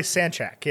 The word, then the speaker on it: Sancak: a young lady by the name of Sarah Sancak. Sanchak? – I have no idea. – S-A-N-C-A-K. How would you pronounce that word? – Probably Sanchak, yeah.